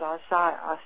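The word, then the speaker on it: açaí: It comes from South America, A C A I, and people pronounce it all kinds of ways. I pronounce it Asaya, Asaya actually.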